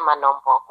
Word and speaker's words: Manhombo: You said my surname wrong. – Of course I did. I just realized that. Please, do help me. – It's Tapio Nashe Manombo. Nice to meet you.